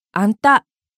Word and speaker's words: あんた: あなたは、あんた、たにアクセント。